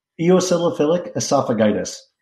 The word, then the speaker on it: eosinophilic esophagitis: Eosinophilic esophagitis.